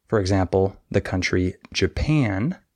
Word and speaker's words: Japan: In this word, the second syllable is stressed Japan. However, many English learners stress the wrong syllable. They say Japan. And that's wrong. But it's very easy to notice if a teacher points that out to you, and it's very easy to fix. You just stress the other syllable.